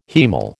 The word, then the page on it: haemal: American English